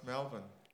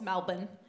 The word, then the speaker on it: Melbourne: It's, of course, Melbourne. You know, like Melbourne.